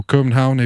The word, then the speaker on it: Copenhagen: Back over to Hirtshals, drive down to Copenhagen, or Copenhagen if you're going for pronunciation in other languages.